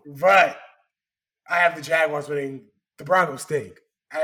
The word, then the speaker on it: Jaguars: The Jaguars. – Yes, because they are in London, so I will pronounce it correctly and call them the Jaguars.